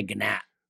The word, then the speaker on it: Gnat: But Gnat would be pronounced the same way, whether the G's there. – I say Gnat.